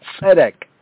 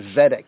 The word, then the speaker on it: tzedek: Now the word tzedek, we touched on it briefly, but the word tzedek, right, tzedek, or one might say in the simple English pronunciation, zedek, zedek, right, zedek. Right, Zedek, or more enunciated Afro-Asiatically, Tzedek, Tzedek, Tzedek, right?